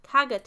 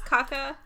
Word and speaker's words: Caga: After hitting the Teo softly with a stick during the song, it is hit harder on the words, Caga Teo. – Okay, that is Spanish. – Yeah. Wait, is that pronounced caca? – No, it's caga.